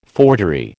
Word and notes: forgery: American English

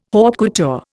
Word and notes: haute couture: Indian

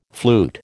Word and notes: flute: American English

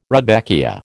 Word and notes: rudbeckia: American English

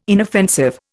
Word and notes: inoffensive: Indian

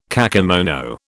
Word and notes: kakemono: British English

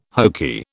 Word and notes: hokey: British English

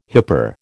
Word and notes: hyper: American English

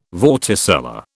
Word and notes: vorticella: British English

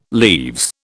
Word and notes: leaves: British English

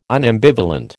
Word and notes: unambivalent: American English

